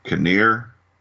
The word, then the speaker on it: Kinnear: Jesse Buckley and Rory Kinnear. Is that how you say it? – Yeah.